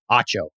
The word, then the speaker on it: Acho: How do you say his last name? – Acho. It's definitely Acho.